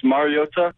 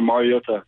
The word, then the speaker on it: Mariota: It's Mariota. So there's not a emphasis on the T. It's just more Mariota.